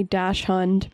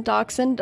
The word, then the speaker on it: dachshund: I always want to say dash hund because I read a lot of books as a kid.